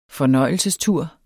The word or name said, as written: fornøjelsestur